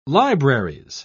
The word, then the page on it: libraries: British English